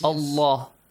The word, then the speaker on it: الله: The word Allah, the lam and the word Allah, it has to be heavy. – Heavily pronounced. Okay? Yes? – Yes. – Allah.